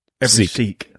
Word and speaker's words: Sikh: Patrick Kirby says, every ski I've ever met is a badass. Okay. Every Sikh. I'm sorry. I messed up.